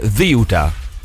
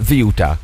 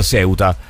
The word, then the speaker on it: Ceuta: Come se ci fosse il TH inglese, Ziuta. Ziuta. Con l'accento rigorosamente sulla prima sillaba, ragazzi.